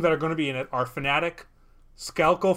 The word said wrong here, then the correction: Schalke: Four. Is that how you say it, right? Schalke. Schalke.